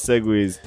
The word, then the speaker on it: segue: When we talk about like segues, when we talk about segues, like changing from one topic to another, that is spelled S-E-G-U-E.